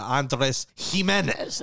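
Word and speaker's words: Andrés Giménez: Love a good Copa Cabana reference, but Andres Gimenez, that's right.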